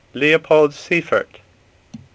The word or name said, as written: Seyffert